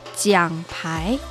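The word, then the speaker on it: jiang pai (奖牌): Medalla en chino se dice jiang pai.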